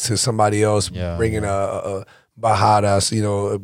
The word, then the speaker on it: Barradas: By the way, why do you guys have two R's, but then you fucking pronounce it with like it's a H? – H. – Bahadas. – Bahadas, but it's two R's. Shouldn't you be saying Barradas?